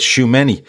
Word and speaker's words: Tchouaméni: So the first one I want to talk about is Tchouaméni at, and I hope I'm saying that right, I asked Keith earlier how I pronounce his name. He said Tchouaméni, the midfielder from Monaco.